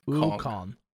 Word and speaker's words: Wukong: Did I say that right? Wugun? Revealed. This one was taken from IGN Joe Screbbles. Black Myth Wargun. Wargun? Wargun? – Wukong. – Wukong? Sorry, say that one more time. – Wukong.